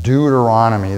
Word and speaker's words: Deuteronomy: Deuteronomy, that's five syllables. You can count them up, five syllables.